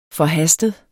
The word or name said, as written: forhastet